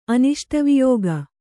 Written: ಅನಿಷ್ಟವಿಯೋಗ

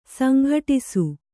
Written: ಸಂಘಟ್ಟಿಸು

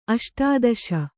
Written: ಅಷ್ಟಾದಶ

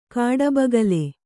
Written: ಕಾಡಬಗುಲಿ

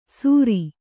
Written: ಸುರಿ

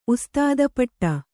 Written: ಉಸ್ತಾದಪಟ್ಟ

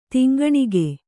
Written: ತಿಂಗಣಿಗೆ

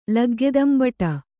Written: ಲಗ್ಗೆದಂಬಟ

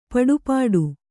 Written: ಪಡುಪಾಟು

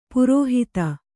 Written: ಪೌರೋಹಿತ

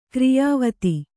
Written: ಕ್ರಿಯಾವೇತ್ತ

